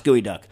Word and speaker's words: geoduck: Yes, spell geoduck, pronounced gooey duck.